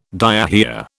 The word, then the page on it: diarrhoea: British English